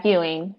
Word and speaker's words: Ewing: Yeah, Ewing, but it's close enough.